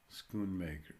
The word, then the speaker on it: Schoonmaker: Schoonmaker. That's like a schooner, yeah.